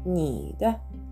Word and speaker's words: 你的: なのでこの 2 文字で合わせて「にだ」というふうに発音してみてください。